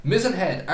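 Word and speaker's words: Mizen Head: You've been dropped to Mizenhead, am I pronouncing that right? – Yeah. – Mizenhead, Ireland's most southerly point.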